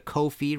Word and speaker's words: Ko-fi: If you liked this show and want to support future episodes of the show, please go to Ko-fi, rhymes with no fee, .com/MartinPopoff.